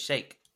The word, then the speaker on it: sheikh: I feel like the British pronunciation is shake. – Shake. Hmm, okay. – That's what I've heard in British media.